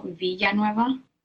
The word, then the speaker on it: Villanueva: Like Spanish, like you pronounce the double L, Villanueva.